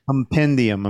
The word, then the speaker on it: compendium: Compodium. – One, two, three. Okay, compodiums. Okay, so that's the complete series, right? – Compendium. I'm sorry. Compendium. I'm mispronouncing this shit.